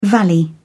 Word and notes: valley: British English

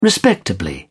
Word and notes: respectably: British English

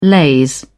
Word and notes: laze: British English

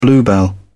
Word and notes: bluebell: British English

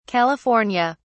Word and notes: california: British English

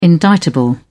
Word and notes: indictable: British English

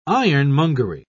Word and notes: ironmongery: British English

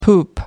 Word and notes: poop out: American English

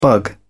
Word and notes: bug: British English

